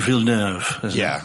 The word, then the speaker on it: Villeneuve: what Villeneuve, is that how you say it? – Denis Villeneuve.